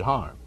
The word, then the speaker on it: Hiram: All natives around Hiram, Georgia, pronounce it Harm.